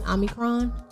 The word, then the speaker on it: Omicron: Omicron.